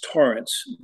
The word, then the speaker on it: Tarrance: It's pronounced like it has an O in it, but it's spelled with an A, and it's technically a hard A. It's really the Gaelic pronunciation, so it's Tarrance.